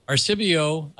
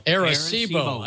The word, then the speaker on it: Arecibo: Angel says it's the 50th anniversary of Arecibo. I didn't say that right. – Arecibo.